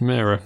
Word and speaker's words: Mara: X Factor is the great Jamie Bell, husband of Kate Mara, whose name apparently is pronounced Mara. I think I'm surprised by that every time I say it.